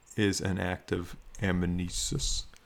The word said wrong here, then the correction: anamnesis: Is an act of remembering. – Exactly. – Wait, one more time. Say it for me. – Anamnesis. – Anamnesis. Okay.